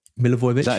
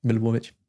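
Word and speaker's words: Milivojević: And I wonder, does that mean Milivojevic? I can't say his name. – Oh, here we go. Milivojevic. – Is that right? – Milivojevic.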